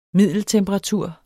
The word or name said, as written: middeltemperatur